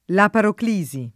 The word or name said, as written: laparoclisi